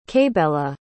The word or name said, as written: kaybella